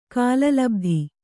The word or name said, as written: ಕಾಲಲಬ್ಧಿ